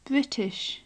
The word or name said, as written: british